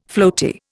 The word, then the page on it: floaty: Indian